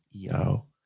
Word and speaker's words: IAO: Isis, Apophis, Osiris, E-A-O, which is I-A-O, but it's pronounced E-O.